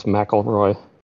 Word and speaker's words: McIlroy: I want to jump on the opportunity to correct your pronunciation. It's McIlroy. – It's McIlroy. Thank you. It's is it misspelled? – No, it's just a weird spelling.